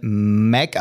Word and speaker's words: Mach: Also, man spricht das Ganze Mac aus, aber es wird M-A-C-H geschrieben, wenn du es googeln möchtest. Also, ich finde es immer absurd, weil Mach, M-A-C-H spricht man auf Englisch Mac, Mac, Mac aus.